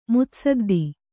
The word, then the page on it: ಮುತ್ಸದ್ದಿ: mutsaddi